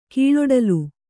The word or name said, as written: ಕೀಳೊಡಲು